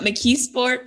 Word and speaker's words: McKeesport: Getting right into things, growing up in, you got to tell me how to correctly pronounce McKeesport. Is that how you say it? Yep, McKeesport, PA.